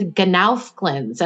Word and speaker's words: GNOWFGLINS: The first one is GNOWFGLINS, and I'm going to spell that for you. It's G N O W F G L I N S, and it stands for God's Natural Whole Foods Grown Locally in Season.